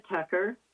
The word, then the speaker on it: Tucker: My name is Mona Tucker, T-U-C-K-E-R, and I'm calling from Arroyo Grande.